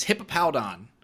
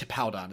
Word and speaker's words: Hippowdon: It is Hippopoudon. So it's a big what in the world. – It's Hippowdon or Hippopoudon. I don't know. Hippowdon. I think it's Hippowdon is how it's pronounced.